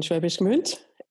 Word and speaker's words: Schwäbisch Gmünd: It was the Fachhochschule in Schwäbisch Gmünd.